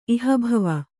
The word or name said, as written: ಇಹಭವ